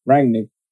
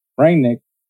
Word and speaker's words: Rangnick: Rangnick. What's so hard about that? – Well, how'd you say it? – Rangnick.